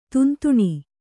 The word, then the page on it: ದಂಧನೆ: kannada